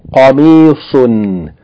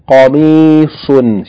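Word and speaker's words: Qamisun (قميص): And the next word is Qamisun with Qaf and the last letter Saad. Please distinguish Qaf from Kaf and Saad from Seen. Qamisun. Qamisun.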